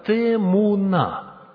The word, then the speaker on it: темуна: Вот это слово «изображение» является переводом древнееврейского «темуна». Ударение на последний слог. «Темуна».